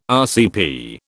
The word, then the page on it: RCP: British English